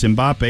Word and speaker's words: Mbappe: So I guess it's Mbappe, but the French say it's Mbappe, and he's French, so I'm going with that.